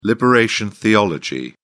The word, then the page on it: liberation theology: British English